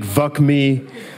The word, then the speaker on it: VUC.me: VUC.me. Some people pronounce it VUC me. I don't, because I don't think that's a very nice sound, especially in Germany.